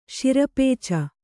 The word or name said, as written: ಶಿರಪೇಚ